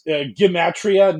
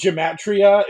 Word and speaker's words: Gematria: it is Gematria, not Gematria. It's a hard G, and that all us Philistines that have been talking about negative 48 have been bricking that.